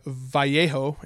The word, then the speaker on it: Vallejo: When you have another company like this other brand, Vallejo, or Vallejo, if you use the Spanish pronunciation.